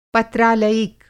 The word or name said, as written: पत्रालयिक